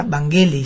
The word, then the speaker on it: Vangelis: Depende quién lo pronuncie, uno escucha distintas fonéticas, pero era de origen griego, ¿no? – Griego. – En forma correcta se pronunciaría Vangelis.